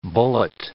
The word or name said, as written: bullet